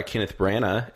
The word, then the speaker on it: Kenneth Branagh: The movie's going to be, it was directed by Kenneth Branagh, and I said it right, guys.